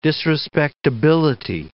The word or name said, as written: disrespectability